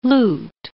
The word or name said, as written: lute